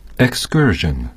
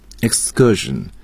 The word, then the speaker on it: excursion: Excursion. – Excursion. 那这个单词呢，大家在学的时候呢，我们的同学一般要注意它的发音，不是大家读的 excursion，应该读 excursion。那么加上 k 要转成 g，轻辅音浊化，这点同学们一定要注意啊。